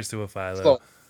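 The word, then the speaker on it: Su'a-Filo: As much as I hate to say it, I think the guard too that everyone's really in love with, Sua Fulu. How do you pronounce his name? – Sua Filo. I would say Sua Filo, but I'm not sure. – Sua Filo. Xavier Sua Filo.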